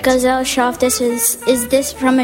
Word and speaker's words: Gesellschaft: Gesellschaft? – Gesellschaft.